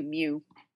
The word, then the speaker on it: miu: but it phonetically spells out M-I-U, mew. – Mew. – Yeah. – Yeah, their word for cat was M-I-U-T,